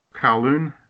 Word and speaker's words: Kowloon: It was based on, how do you pronounce it, Kowloon? – Kowloon.